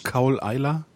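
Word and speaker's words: Caol Ila: Wie schreibt man Cowl Isler? – C-A-U-L und dann neues Wort I-L-A. – Auch wie man spricht sozusagen, ja, okay.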